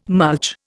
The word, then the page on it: mulch: Indian